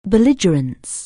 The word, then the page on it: bellhop: anglais